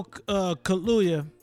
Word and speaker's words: Kaluuya: Daniel Kaluuya.